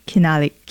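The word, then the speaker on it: Xinaliq: Is it pronounced Hinalik? – Yep, Hinalik.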